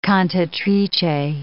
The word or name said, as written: cantatrice